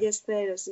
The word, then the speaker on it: Ballesteros: Ballesteros, yeah. In Italian it's Ballesteros, but in Spanish it's Ballesteros.